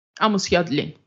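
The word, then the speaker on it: Amos Yadlin: این ماجرای توییت های اموس یدلین چیه درست اگه درست تلفظ کنم اموس یدلین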